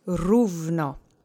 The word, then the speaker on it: równo: You've got the O tick mark, which, reminder, is a U sound, U. So let's just say równo. Accent is on the first syllable. Try to roll the R if you can. If not, don't sweat it.